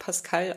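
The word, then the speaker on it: Pascale: Ja, der wird mit E geschrieben, wird Pascal ausgesprochen, wie der junge Name in Deutschland und ist französischen Ursprungs.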